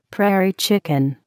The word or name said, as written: prairie chicken